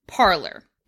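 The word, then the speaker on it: Parler: I didn't know it wasn't pronounced parlor. – Well, that's the thing. It is now pronounced parlor. It was. – Because they're too xenophobic for it to be parlay. Well, kind of. It's spelled like the French word parlay, which means to speak. And it was parlay.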